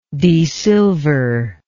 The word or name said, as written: desilver